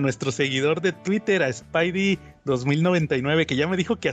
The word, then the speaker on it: Spidey 2099: Aquí, aquí Spidey 2099, veo que ya lo pronuncian correctamente. Spidey 2099.